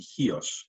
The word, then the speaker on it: Chios: Mastiha and Chios, yes.